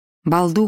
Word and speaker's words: Балду: Балду Хаус, в 5 милях к западу от Пензанса. Еще мили по извилистой узкой дороге Лэнс-Энда. Произносится как «Балду».